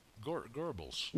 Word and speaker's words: Goebbels: Remember, in the 25 Rules of Disinformation by Gobel, the info propaganda meister for Hitler's info machine. – Goebbels. – Yeah. There's no R in it, but that's how they pronounce it.